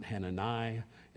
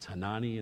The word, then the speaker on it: Hanani: That's how it's pronounced. It's not Hanani. It's Hanani in the Hebrew.